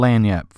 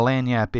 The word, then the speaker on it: lagniappe: And now we actually have a little lagniappe for y'all. And if you're not sure what a lagniappe is, it's, and don't try to spell it because it's not L-A-N-Y-A-P, trust me. It's L-A-G-N-I-A-P-P-E.